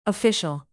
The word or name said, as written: official